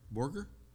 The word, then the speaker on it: Borger: Is that Borger? Okay, it's a hard G.